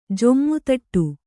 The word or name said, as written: ಜುಮ್ಮುದಟ್ಟು